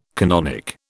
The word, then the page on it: canonic: British English